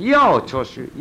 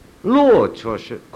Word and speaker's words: 乐: 在庄子的文字里头，我们后世读法可以分两点，一个可以读成“ 要” 出虚，应要的“ 要”；一个也可以读成“ 乐” 出虚，快乐的“ 乐”。